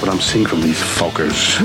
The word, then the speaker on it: Fockers: How do you pronounce it? – Oh, just like it's spelled. F-O-C-K-E-R. – Meet the Fockers in theaters December 22nd.